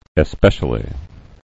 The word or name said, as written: especially